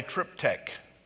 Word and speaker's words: triptych: But here, there is a word that is called a triptych. That's the way you pronounce it.